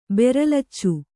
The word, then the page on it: ಬೆರಲಚ್ಚು: beralaccu